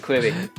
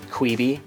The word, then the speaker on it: Quibi: there was an employee at some point who was like annoyed with people pronouncing it Quibi. – Yeah. Eh, they're like, it's not Quibi, it's short for Quick Bites.